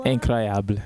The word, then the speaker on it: Incroyable: Incroyable. – Incroyable, yeah.